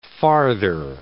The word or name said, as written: farther